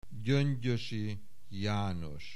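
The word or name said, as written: Gyöngyösi János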